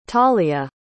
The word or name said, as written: talya